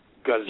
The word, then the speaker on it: GUZOO: But it's G as in goat, U as in unicorn, Z-O-O, GUZOO. – Yes. – So it's not G-A, but it's GUZOO.